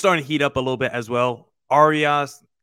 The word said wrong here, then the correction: Arraez: how do you pronounce the name? Arias, is that how you say it? – Luis Arraez.